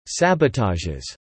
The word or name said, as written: sabotages